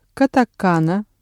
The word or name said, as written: катакана